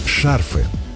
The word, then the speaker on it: шарфы: Шарфы. Во множественном числе этого слова ударение часто переносят на окончание.